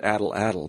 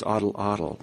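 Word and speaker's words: atlatl: So you maybe you could explain a little bit I always pronounced it addle-addle, but it's oddle-oddle.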